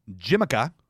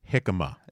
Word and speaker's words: jicama: It's avocado, cabbage, cauliflower, jimica. – Jicama.